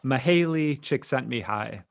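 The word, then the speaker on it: Mihály Csíkszentmihályi: So this is, I'm going to do my best here, but Mihaly Csikszentmihalyi.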